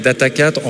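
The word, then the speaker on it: Data4: Donc moi, je prononce en fait Data 4, en fait, avec le... puisqu'on est très local, on dit Data Quattro, Data 4, Data Sphere.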